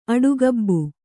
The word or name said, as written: ಆಡುಗಬ್ಬ